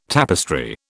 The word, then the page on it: tapestry: British English